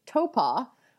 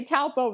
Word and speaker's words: Taupo: So the correct pronunciation is Topa, which I try and say right, but it's so tempting to just say Taupo because that's how your Americanness wants to be like coming out, right?